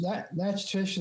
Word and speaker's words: Natchitoches: I understand that that's pronounced Natchitoches, Natchitoches. Is that right? – Pretty close. Natchitoches is how I've heard it pronounced also, with a different accent on the A, but that's right. It's not what it looks like.